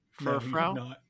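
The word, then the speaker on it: Furfrou: Frufrau. Did I say that right? – Furfrau.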